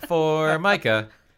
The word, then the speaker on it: Formica: It's pronounced Formica.